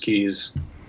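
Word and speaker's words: cays: and fishes the Jolters Keys. And I say keys, it's spelled CAYSKs, but the Bahamians right. – Pronounce mm-hmm. keys.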